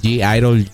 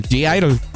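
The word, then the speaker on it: (G)I-DLE: Esto es de, no sé cómo se dice, G-Idol, g Gyrol, no sé, ¿cómo se dice? Tienes un minuto para decirme cómo se dice. g Gyrol, el Gyrol. No, ¿cómo se dice? G-Idol, G-Idol. Idol. Ok, sí.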